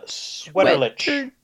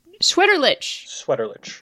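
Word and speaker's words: Sweterlitsch: It is, Tom Sweaterlich. – Sweaterlich. Of course, Tom Sweaterlich has sweater in the name.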